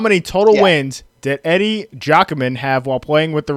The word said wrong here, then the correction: Giacomin: Wait, are you sure about that pronunciation of his name? No, I think it's Giacomin.